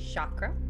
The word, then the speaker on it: chakra: So, most people say chakra, but it's actually really pronounced chakra.